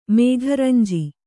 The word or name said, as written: ಮೇಘರಂಜಿ